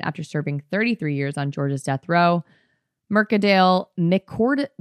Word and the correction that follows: McCorkadale: wait, I think I've been saying his name wrong this entire time, which I don't give a fuck about because fuck this guy, but Mick Corkadale. His name is Mick Corkadale.